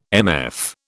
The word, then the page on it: MF: British English